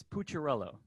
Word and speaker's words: Pucciarello: It is Pucciarello.